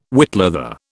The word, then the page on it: whitleather: British English